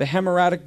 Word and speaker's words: hemorrhagic: In 1989, the hemorrhagic disease was, am I saying that right? Hemorrhagic, thank you.